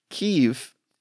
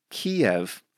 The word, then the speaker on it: Kyiv: Kyiv is close to what they say in Ukrainian, but I grew up calling that city Kiev, which is more aligned with the Russian pronunciation of that word.